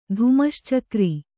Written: ಧುಮಶ್ಚಕ್ರಿ

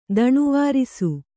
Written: ದಣಿವಾರಿಸು

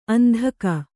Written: ಅಂಧಕ

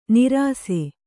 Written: ನೀರಸ

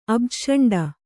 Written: ಅಬ್ಜಷಂಡ

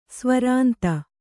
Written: ಸ್ವರಾಂತ

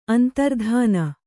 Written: ಅಂತರ್ಧಾನ

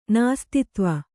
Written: ನಾಸ್ತಿತ್ವ

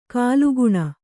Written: ಕಾಲುಗುಣ

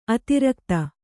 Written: ಅತಿರಾಗತೆ

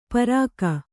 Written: ಪಾರಕ

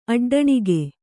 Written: ಅಡ್ಡಣಿಗೆ

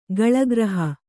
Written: ಕೇಲಿಗೃಹ

